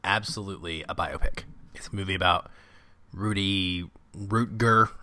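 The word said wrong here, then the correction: Ruettiger: or how do we say his last name? – Rudiger. – I was so close.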